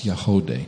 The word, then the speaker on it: Yagody: Y-A-G. O-D-Y, but it's pronounced Yahodeh.